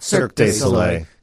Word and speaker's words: Cirque du Soleil: as when Cirque du Soleil, did I say that right? – Cirque du Soleil.